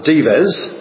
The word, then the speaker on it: Dives: He's usually referred to as Dives, is how it's spelled, but Dives.